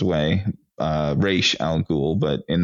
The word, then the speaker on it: Ra's al Ghul: As well as it's not the concept of Ra's al Ghul,